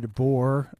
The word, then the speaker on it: DeBoer: Peter DeBoer, I hope I'm pronouncing that correctly.